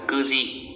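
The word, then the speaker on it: kuji (九時): Yoji, 9 giờ thì là kuji, kuji nhá, 9 là kyu. Thế nhưng mà đây là trường hợp đặc biệt nên đọc là cư, kuji, đấy là cách nói giờ.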